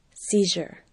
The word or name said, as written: seizure